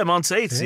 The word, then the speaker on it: Monteith: Mo- is it is it Monteith? Yeah, Monteith.